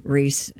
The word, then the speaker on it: Rhys: Does he pronounces it Rhys? – Rhys. – Does he? I don't know. – Oh, it is Rhys.